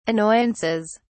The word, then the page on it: annoyances: British English